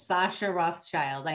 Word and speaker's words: Sascha Rothchild: Sascha Rothchild.